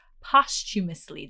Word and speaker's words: posthumously: I found out after I bought it that this book was published, actually, I can never say this word, posthumously. Did I say that right? – Yes. That was well done.